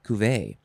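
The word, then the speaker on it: cuvée: Or cuvee, or cuvee, it's a very French way, cuvee. It is spelled C-U-V-E-E,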